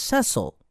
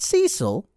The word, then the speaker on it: Cecil: Cecil, yes, it's pronounced Cecil, not Cecil, is the waiter at Dot's Diner in Mainframe.